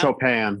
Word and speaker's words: Chopin: It's Chopin, yeah.